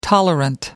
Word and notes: tolerant: American English

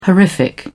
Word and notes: horrific: British English

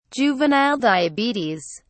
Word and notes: juvenile diabetes: British English

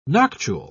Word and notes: noctule: British English

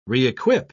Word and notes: re-equip: British English

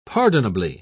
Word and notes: pardonably: British English

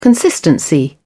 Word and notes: consistency: British English

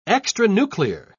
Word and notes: extranuclear: British English

